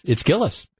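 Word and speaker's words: Gilles: But you know, it's Gillis. Gillis's is the way you pronounce it, according to the Journal Sentinel story.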